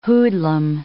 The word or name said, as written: hoodlum